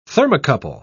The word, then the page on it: thermocouple: British English